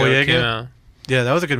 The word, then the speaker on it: Boyega: Yeah, and then John Boyega, I think that's how you say his name. – Boyega.